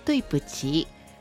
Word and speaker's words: 对不起: 对不起。对不起。 – はい、对不起。一対一、ついという字に否定の不、そして起きるで对不起という風な発音になります。